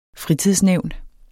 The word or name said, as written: fritidsnævn